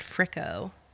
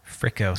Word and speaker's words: Friko: Crimson to Chrome by, I think they pronounce their band Fricko. – Fricko, spell it. – F-R-I-K-O. – Fricko.